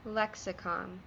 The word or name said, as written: lexicon